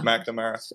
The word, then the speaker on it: McNamara: McNamara. – It's close enough. – It's Mick. You know, it's Mick.